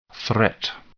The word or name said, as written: threat